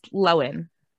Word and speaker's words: Lohan: How did she go all this time and everybody's been pronouncing it wrong, and she's never made a point to be like, by the way, guys, it's Lohan.